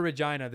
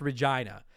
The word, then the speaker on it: Regina: There's a city in Saskatchewan here in Canada that's spelt exactly the same as Regina, but it's pronounced Regina.